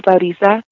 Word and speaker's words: Laurisa: So when she said Laurisa, because there's a Y sound in my name,